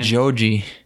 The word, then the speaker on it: George: That's how George is pronounced in Japanese. – I know. It's fun to say it as Georgie. – That's true. – That is Georgie. – It's more like Joji.